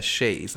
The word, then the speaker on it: chaise: I always mess up how to pronounce that properly. A chaise, I think it's how you pronounce it. – That's what it is, yeah.